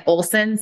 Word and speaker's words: Oleson: So Ann without an E, and Olson spelled very weirdly. So it's spelled O-L-E-S-O-N. So Ann without an E, throw the E into Olson and you get it.